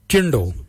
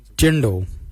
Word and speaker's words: djendal: Let's look at jindal. Jindal means white. Jindal. Jindal is spelled D-J-E-N-D-A-L, Jindal. Then we'll look at Jardak. Jardak is grey. Jardak. Jardak is spelled D-J-A-R-D-A-G, Jardak, grey.